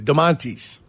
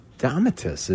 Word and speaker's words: Domantas: Dolomatis. Sabonis. – There's no L in his name. – It's not an L? Oh, I see. Domantas. – It's Domantas, isn't it?